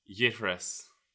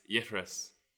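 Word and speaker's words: Leithreas: So the word is yitres. Yitres. – I don't know. Can I have a clue? – So I'll spell it for you. It's L-E-I-T-H-R-E-A-S, and it's pronounced Yitris.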